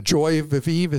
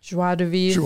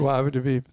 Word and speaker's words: joie de vivre: Joy de vivre, is that how you say it? – Joie de vivre. – Joie de vivre, thank you.